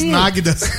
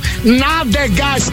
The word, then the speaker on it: nádegas: Você falou duas vezes náguidas. – Pelo amor de Deus, é porque eu fiquei com vergonha um pouco de falar, mas eu falei certo, nádegas, que é o bumbum.